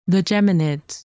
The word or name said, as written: Geminids